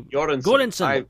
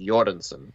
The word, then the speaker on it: Göransson: It's a Swedish word, and the G is pronounced with a Y sound. Göransson.